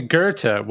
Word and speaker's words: Goethe: I remember once quoting Goethe and being reminded, oh, it's actually Goethe, which is not at all how one would think that word would be pronounced.